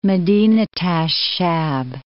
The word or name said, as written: Madinat ash Sha'b